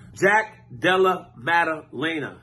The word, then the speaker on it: Jack Della Maddalena: Jack Della Maddalena.